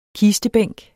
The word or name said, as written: kistebænk